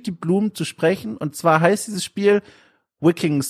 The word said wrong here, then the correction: Viking: City Builder. Oh Gott, ich hab's falsch ausgesprochen. Viking City Builder.